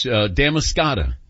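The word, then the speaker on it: Damariscotta: Yes, Damascata is the correct pronunciation.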